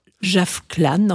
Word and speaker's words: Javkhlan: Il paraît qu'on ne peut pas aller en Mongolie sans entendre ce chanteur qui s'appelle Javklan. Enfin, ça s'écrit J-A-V-K-H-L-A-N.